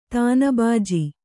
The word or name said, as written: ತಾನಬಾಜಿ